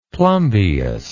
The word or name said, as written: plumbeous